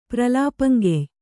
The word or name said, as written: ಪ್ರಲಾಪಂಗೆಯ್